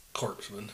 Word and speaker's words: corpsmen: Corpsmen. Like dead guys? – Supposed to be corpsmen. Yeah. Yeah. Don't know how to say the P and S are silent.